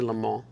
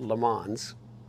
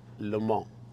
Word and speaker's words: Le Mans: And I say Le Mans because I was a French major in college and I studied in Paris. So that's how you pronounce it. Not Le Mans, Le Mans.